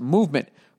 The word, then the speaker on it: MVMT: With fair prices, unexpected colors, and clean original designs, MVMT, pronounced Movement, grew into one of the fastest-growing watch brands, shipping to over 160 countries across the globe.